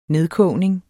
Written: nedkogning

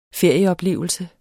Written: ferieoplevelse

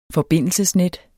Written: forbindelsesnet

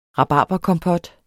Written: rabarberkompot

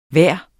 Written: vejr

